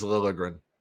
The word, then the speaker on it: Liljegren: Timothy Liljegren, whatever the how he pronounce his name. – I think it's Liljegren.